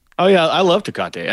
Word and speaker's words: Tecate: he's bringing a Tecate. Did I say that right? The Tecate beer? – Oh, the Mexican beer, Tecate? – Yeah, Tecate, yeah. I was like, of all fucking beers to be drinking in this fucking movie, you got that one out there.